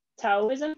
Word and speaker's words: Taoism: And he is, I guess, one of the founders of, and I hope I pronounce this right, Taoism. Is that right? – Oh yeah.